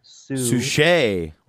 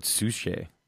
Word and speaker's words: Suchet: It stars Peter Capaldi as the Doctor, Pearl Mackey as Bill, Matt Lucas as Nardole, and David Schuchat as the landlord. Did I say that right? – Suchet. – Like sachet. Like sachet. – Like sure, why not? – But Suchet.